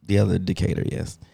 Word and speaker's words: Decatur: Decatur, Illinois. D. Peoria, Illinois. Did I say that right? – Peoria. Nobody goes there. That's cool. – Peoria. – Decatur. – Decatur was good.